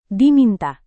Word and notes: diminta: indonesian

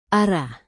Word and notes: arah: indonesian

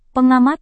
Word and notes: pengamat: Indonesian

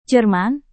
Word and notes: jerman: Indonesian

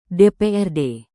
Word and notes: dprd: Indonesian

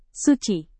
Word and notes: suci: Indonesian

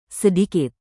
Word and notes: sedikit: indonesian

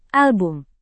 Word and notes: album: indonesian

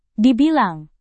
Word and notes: dibilang: Indonesian

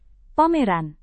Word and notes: pameran: Indonesian

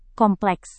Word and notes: kompleks: Indonesian

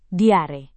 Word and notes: diare: Indonesian

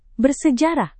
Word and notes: bersejarah: Indonesian

